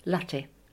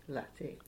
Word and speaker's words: latte: Latte. – Latte. – Yes. – With an A sound, as in? – Short A, yeah.